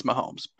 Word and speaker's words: Mahomes: Mahomes, however you pronounce his last name. It's Mahomes, but you can pronounce it any way you want because he's a god, and he's a rich billionaire.